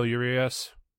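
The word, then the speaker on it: Urías: Julio Urias. I know I'm probably pronouncing that wrong. – Oh, you got it.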